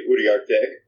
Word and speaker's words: Uriarte: I had my name plastered on my chest for 4 years, and nobody ever was able to pronounce it correctly. It's technically Uriarte, which is Spanish-Mexican name.